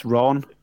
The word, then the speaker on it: Iran: It's not the Ran at all. It's Ron.